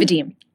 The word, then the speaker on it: Vadim: Is that I say that right? – Vadim. – Oh, Vadim.